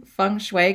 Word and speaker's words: feng shui: Am I saying feng shui correctly? – You are. You are saying it absolutely correctly.